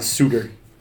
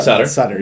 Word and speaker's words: Suter: It's Ryan Suter. – Yeah, not Sutter. Right, exactly. – Well, there's only one T, guys, clearly.